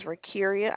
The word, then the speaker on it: Rakiria: And my name is Rakiria. I can spell that for you as well. – Okay, thank you. – Yes, ma'am. That is R-A-K. – R-A-K. – I-R-I-A.